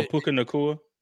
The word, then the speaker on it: Puka Nacua: Oh, Puka Nacua.